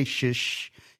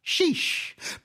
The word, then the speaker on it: Shish: And you got to say it like they can't say shish. You can't say shish. You got to say sheesh.